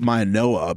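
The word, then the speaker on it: Mauigoa: Maioa, apparently. Apparently it's a Noah. I didn't realize that until yesterday, but that's what we were told, Maioa.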